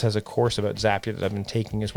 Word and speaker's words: Zapier: Zapier rhymes with happier, and it makes my work life happier, and it'll do the same for you.